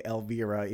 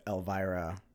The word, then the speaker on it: Elvira: So during that song, she pronounced Elvira as Elvira, and I'm guessing that I probably said it wrong. – Well, she certainly didn't. She's in the show. – So I think from now on, I will say Elvira, even though when I see the name, I think of Elvira. – Queen of the Dark? – Correct. Same spelling. – Yeah. So I think it's just a British pronunciation thingy-mahoo.